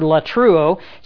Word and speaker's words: latreuo: And the word for service here is the word latruo. It's a word that means priestly service. It's spelled L-A-T-R-E-U-O, and you find it in Romans 12 and verse 1.